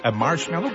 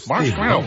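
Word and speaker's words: Marshmello: La preuve que vous êtes au meilleur de la musique, c'est Marshmallow, comme ça on le prononce. – Ouais, tu l'as bien. – Marshmallow.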